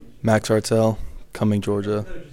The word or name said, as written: Max Haertel